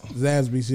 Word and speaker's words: Zaxby's: Zaxby's? – Zaxby's, yeah.